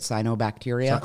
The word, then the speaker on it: cyanobacteria: how do you say it, cyanobacteria. – correct.